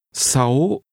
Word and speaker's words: sáu: 6 is pronounced with a high rising tone. – 6. – Say 6. – Sáu.